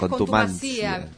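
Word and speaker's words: contumacia: La palabra correcta es contumacía. Contumacía. – Con acento en la I. – Sí, y se escribe con C.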